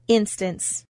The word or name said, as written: instance